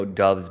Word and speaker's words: Doubs: Yeah, and we're going to call him Romeo Doubs because every pronunciation guide I can find has him uh, as Doubs.